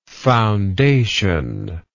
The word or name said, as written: foundationary